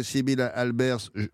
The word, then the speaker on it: Sibylle Albers: D'abord, avant de décrire ça, un mot sur Gottfried Honegger et Sibylle Albers. Je prononce bien, là, ça va ? – Oui, tout à fait, c'est ça.